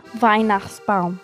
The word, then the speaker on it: Weihnachtsbaum: Weihnachtsbaum. – Mm-hmm. – Did I say it right? – Yep.